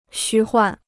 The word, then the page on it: 虚幻: xū huàn